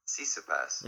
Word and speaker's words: Tsitsipas: Tsitsipas.